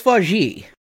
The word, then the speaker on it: Vaugier: When Elizabeth Robinson, played by Emmanuel Vaugier. One moment, please. – You're going to look up her pronunciation? – No, it's Vaugier.